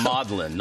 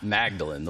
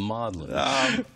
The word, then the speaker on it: Magdalen: The Magdalen, not Magdalen, the Magdalen. – What's the significance of that? – It's significant. The Magdalen fragment, P64, that's Papyrus 64, is the second oldest New Testament fragment that we have.